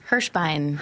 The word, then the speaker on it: Hirschbein: Hirschbein.